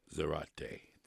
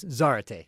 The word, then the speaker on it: Zarate: That was a live performance of the guitarist Raul Garcia Zarate. Did I say it correctly? – Close. Zarate. – Zarate. – Yeah, Zarate.